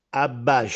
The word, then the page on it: Abbàsce: Castellanetano